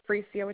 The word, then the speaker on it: Freesia: Yeah, my first name is Freesia, which is spelled F as in Frank. R-E-E, S is in Sam, I-A.